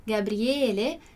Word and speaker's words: Gabriele: E todos esses nomes que terminam em L, que a gente diz no português, mas no italiano a gente vai pronunciar ele, como Daniele, Gabriele, são nomes masculinos, ok?